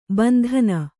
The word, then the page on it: ಬಂಧನಿ: Kannada